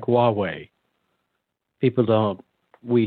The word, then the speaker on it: Huawei: And, but there's an H. Now we don't hear the H, we just hear Huawei. So I think Americans would spell that thinking there's a W there, but there's not, it's an H, H-U-A.